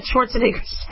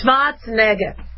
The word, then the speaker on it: Schwarzenegger: It's Schwarzenegger, is I think how you pronounce it in Austrian, is very dangerous for Democratic Governor Gavin Newsom.